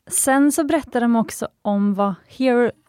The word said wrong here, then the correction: heirloom: eller heirloom, så uttalas det väl, jewelry är.